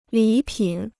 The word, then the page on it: 礼品: lǐ pǐn